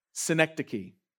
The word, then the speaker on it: synecdoche: S-Y-N-E-C-D-O-C-H-E. Synecdoche. Say it with me. Synecdoche. Yeah, you got it. Okay. So what is a synecdoche?